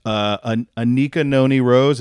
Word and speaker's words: Anika Noni Rose: Anika Noni Rose, is that how you say her name? – Yes, she's a queen.